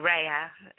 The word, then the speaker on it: Rhea: I have access to my daughter, Rhea, or Rhea, as it is properly pronounced, R-H-E-A.